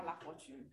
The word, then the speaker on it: LaFortune: Nirva Rebecca LaFortune.